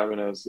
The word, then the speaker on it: Ibanez: So, you know, you're an Ibanez guitars fan, if I said that right? – Yeah, Ibanez. Yeah, I've been playing them since I was, year 7 or year 8.